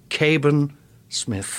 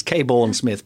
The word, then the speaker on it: Cabourn-Smith: I've got lovely Margaret Cabourn-Smith. – Hello. – I pronounced that deliberately wrong, slightly. It's Cabourn-Smith, but Cabourn.